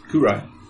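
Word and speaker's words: Kyrai: Kurai. – Kurai? – It's spelled, I've got the character. – K-Y-R-A.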